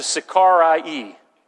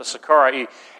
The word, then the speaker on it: Sicarii: There was a subgroup of the zealots, and the way that you pronounce that is the Sicarii. That's how you pronounce that, the Sicarii.